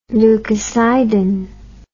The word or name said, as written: leukocidin